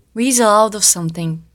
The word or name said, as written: weasel out of something